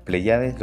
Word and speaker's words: Pleiades: Ahí está, Pleiades, lo dije bien, sí, perfecto.